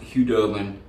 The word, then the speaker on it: Khudobin: I know it's pronounced Khudobin, but whatever. I don't care. I'm stuck in my ways.